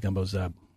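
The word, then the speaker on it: gumbo z'herbes: The French way of pronouncing it is gumbo fine herbes. But the short name for it in New Orleans and South Louisiana is gumbo zeb.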